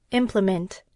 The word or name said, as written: implement